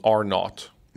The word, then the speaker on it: R-naught: Because so when it comes to diseases, there's something called R-naught. Mm-hmm. Which is R0, but the zero obviously is not like nautical kind. So it's spelled R-NAUGHT.